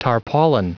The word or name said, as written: tarpaulin